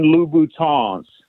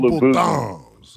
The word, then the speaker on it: Louboutin: And then most importantly, so the women that listen to the show don't start tagging you on YouTube, they are Christian Louboutins. You got to pronounce it with the French Louboutin. – I'm sorry. – You can't go Louboutins.